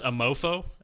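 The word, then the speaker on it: Amour Fou: That's pronounced a mofo.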